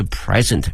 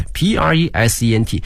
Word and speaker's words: present: The presents 啊，注意一下这个词 presents 礼物。我们想到礼物啊有两个词，一个是 gift， GIFT 可以哈，还有一个呢是 present PRESENT 这个词啊，如果表示礼物的话呢，它的发音是 present 啊，注意一下它的发音呢，重音在前面哈 present。